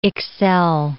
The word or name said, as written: excel